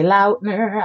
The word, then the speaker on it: Lautner: But speaking of Twilight, there was a mass hysteria when Taylor Lautner pronounced his name Taylor Lautner on TikTok. Apparently, the entire world has been saying Lautner. – Lautner. – Lautner. – Lautner. – Lautner. Okay, he says then. Lautner. It's Lautner.